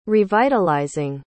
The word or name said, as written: revitalizing